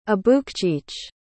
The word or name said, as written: abukcheech